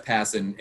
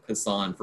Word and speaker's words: Passan: He is ESPN's national baseball writer, Jeff Passan. And yes, I YouTubed it earlier today to make sure I got the pronunciation right, because I've been saying Passan for a very long time.